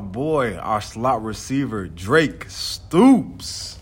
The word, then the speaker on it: Stoops: Let me make sure I say his name right, Sooner Nation. I'm going to say it the way y'all say it. Stoops.